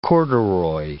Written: corduroy

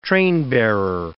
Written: trainbearer

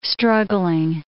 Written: struggling

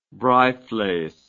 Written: braaivleis

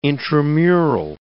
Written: intramural

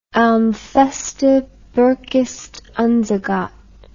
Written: ein feste burg ist unser gott

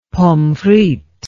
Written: pommes frites

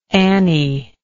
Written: annie

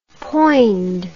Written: poind

